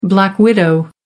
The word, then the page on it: black widow: American English